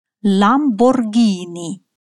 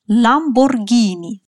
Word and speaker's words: Lamborghini: И во всех остальных случаях читается как звук «г». «Регало», «гранде», «густо», «гонна», «гатта», «ламборгини». Да-да-да, именно Lamborghini – знаменитая марка итальянских машин.